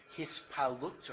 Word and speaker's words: Hispalut: Hispailus, or in Hebrew you pronounce it Hispalut, or Hitpalut, is emotional arousal.